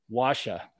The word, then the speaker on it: Wascha: Wascha, not wax yep.